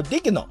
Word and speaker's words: Dieghino: 它的名字呢叫 Digno 啊，拼一下是 D I E G H I N O Digno。